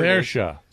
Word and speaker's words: Saoirse: She had a lesbian sex scene with Cerise, or however you say her name, Cerise, whatever Saoirse. – Saoirse.